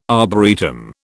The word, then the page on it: arboretum: British English